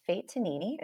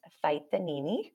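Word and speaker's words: Faetanini: It's spelled F as in Frank, A E, T as in Tom, A-N-I-N-I. It's Italian. In Italy, it would be pronounced Fight the Nini, but, you know, I've Americanized it for...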